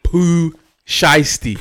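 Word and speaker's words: Pooh Shiesty: Listen, first you couldn't pronounce words. Now you can't pronounce rapper's name. – I don't know his name. – It's Poo Shiesty.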